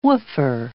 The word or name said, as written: woofer